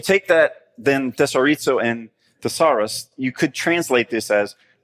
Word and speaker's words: thesauros: And that's the Greek word thesauros. Wait, that sounds like the first word. It does. It also sounds like the word thesaurus, right?